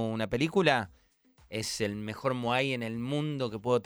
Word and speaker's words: Moai: Moai.